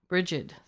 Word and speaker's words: Bridget: That is a, there's a proper way and an improper way. – And Bridget, which is which? – Bridget. Soft G is proper. – Soft G. – Soft G. – Instead of a hard G.